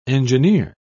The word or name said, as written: engineer